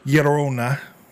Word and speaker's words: Llorona: But I'm not that good at it, but if you try to pronounce it like it is, it kind of naturally starts to happen. – Yeah. – Llorona.